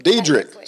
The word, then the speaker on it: Dederick: Dederick.